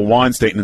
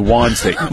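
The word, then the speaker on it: Juwan: By the way, when did it turn into Juan Staten instead of Jawan Staten? – Oh, I didn't even know that. – I noticed Tony last night. It was the soft J. It was Juan Staten. – Okay. – Staten. I always thought it was Jawan Staten.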